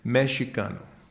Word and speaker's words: Mexicano: In Nahuatl, the X is pronounced like chi. So the Spanish would say Mexicano. The Indian, Nahuatl, would say Mexicano.